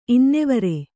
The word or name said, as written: ಇನ್ನೆವರೆ